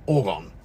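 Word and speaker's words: orgone: Apparently that's how you pronounce it. So I watched a special on Brock last night and he pronounced it in the show. Orgon.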